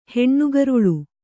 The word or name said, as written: ಹೆಣ್ಣುಗರುಳು